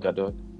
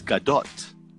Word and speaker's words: Gadot: You don't pronounce it gado. It's Gadot. – Gadot. – Because she's from Israel.